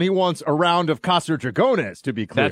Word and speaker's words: Casa Dragones: You can pronounce it better. – Casa Dragones. – Dragones. All right. I would basically pronounce it Casa Dragons, right? Dragones.